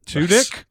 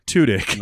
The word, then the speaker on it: Tudyk: He said, if you're going to, you know, laud this guy, you have to say his name correctly, because we say Tudyk sometimes. And he was like, think about a porn threesome and not the cool kind. And then that's the way that you pronounce it.